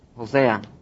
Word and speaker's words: Oseas: Oseas, tengo que aprenderme esos nombres, Oseas 11:1.